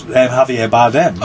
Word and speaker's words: Javier Bardem: See, I know how to pronounce Javier Bardem.